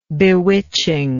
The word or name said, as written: bewitching